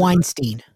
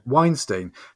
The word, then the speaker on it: Weinstein: Weinstein. – Weinstein.